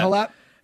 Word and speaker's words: Halep: Yeah, Halep.